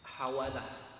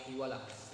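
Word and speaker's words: hawalah (حوالة): Dhubita fil matni bi fathin. Naam. Dengan fathah al-muhmalah. Juga sebagian membacanya dengan kasrah ha-muhmalah. Jadi dibaca hawalah, ada yang membaca hiwalah.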